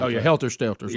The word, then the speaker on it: Stelter: And then And then we have this this college freshman that's going to talk to CNN's, how do you say his name, Brian Stelter? Is it Stelter? – Stelter.